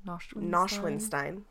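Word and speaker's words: Neuschwanstein: How do you say it? – Nashwinstein.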